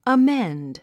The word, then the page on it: amend: əménd; アメェンド